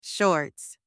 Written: shorts

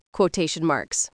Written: quotation marks

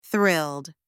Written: thrilled

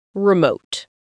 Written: remote

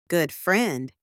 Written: good friend

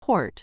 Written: port

